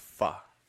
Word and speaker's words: pho: It's pronounced pho. – Pho. Bitch. – Pho. – I'm a Mexican. I say that. Fuck that one. – Fo. – Fo. We're going to comer po. Po. – Acento en la O.